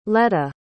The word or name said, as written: leota